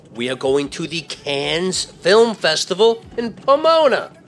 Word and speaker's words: Cannes: It's Cannes. Silent S, okay? And you can't drive there. – First up, it's Cannes with a loud S.